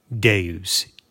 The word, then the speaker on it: Deus: Now, towards the end of Devs, we learn that Devs, spelled D-E-V-S, was often in its conception referred to as Deus, D-E-U-S, the V then being a Roman V, which is pronounced like a U.